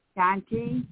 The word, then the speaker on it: Daunte: Now, the first one is, police union praises cop who injured Dante Wright's mother. I hope I said his name right. D-A-U-N-T-E. Dante, okay.